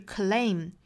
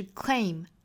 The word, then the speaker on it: claim: 前面的两个辅音连缀呢，中间不要加音，不是 claim。应该是 claim 干脆利落的一个音节的词